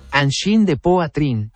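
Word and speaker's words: Angine de Poitrine: Angin de Poitrine, que significa angina de pecho, y es el nombre del popular dúo canadiense de rock, se pronuncia en francés aproximadamente como Angin de Poitrine.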